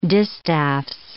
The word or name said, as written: distaffs